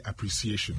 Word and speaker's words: appreciation: Now, we're learning the pronunciation because some of us will actually say appreciation. Appreciation. Mm. I beg you. Just as you say appreciate, add the shin to it and it becomes appreciation.